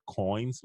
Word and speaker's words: Qoins: It's called Coins, like coins and money, but it's spelled Q-O-I-N-S.